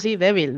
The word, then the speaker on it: Devil: Joder, ah, bueno, esa eres tú la que sabes pronunciarlo. Tú me dices débil y yo me lo creo. – Sí, débil, me parece bien.